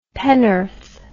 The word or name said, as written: pennorth